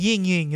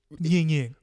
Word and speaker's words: Ying Ying: Well, but it's like Ying Ying. That's the right way to say that. Ying Ying. – Right. – But Li Li, I'm not sure because it's L-E-L-E.